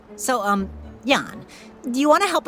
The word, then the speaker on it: Jan: I'm Jan. It's pronounced like yawn, but spelled with a J.